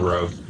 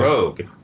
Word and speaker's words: Rogue: And the argument was how to pronounce her name. Yeah. Rogue. He was absolutely certain that it was pronounced Rogu.